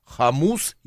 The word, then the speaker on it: хомус: Ну и у нас еще программа будет наша афиша, у нас сегодня будет экзотический инструмент в студии звучать, называется он хомус или варган? – Хомус. – Хомус. – На у ударение.